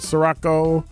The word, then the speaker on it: Sirico: And we shall also remind you that Concordia University cordially invites you to an evening with two American heroes, Father Robert Sirico. I keep saying his name wrong. It's Sirico, S-I-R-I-C-O, founder of the Acton Institute.